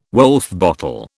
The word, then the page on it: Woulfe bottle: British English